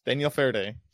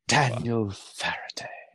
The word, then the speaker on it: Faraday: Daniel Faraday could be a fault. – You're saying it wrong, though. Daniel Faraday. God.